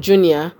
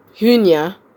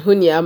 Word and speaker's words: Junia: He said, verse 7 of Romans 16, he said, greet Andronicus and Junior, or Junia, as they pronounce it, greet Andronicus and Junia, my fellow Jews who were in prison with me.